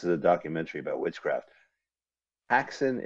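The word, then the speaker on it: Häxan: Carl, we are watching Haxan, Witchcraft Through the Ages, a 1922 silent movie from Denmark, from Switzerland, from over there.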